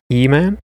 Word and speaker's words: Iman: I'm man. – How do you pronounce that first vowel? – I think it's E. – E man? – Yeah.